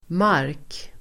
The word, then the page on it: mark: [mar:k]